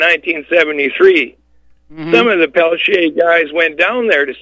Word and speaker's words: Peltier: Well, and we pronounce it Peltier, but it's okay.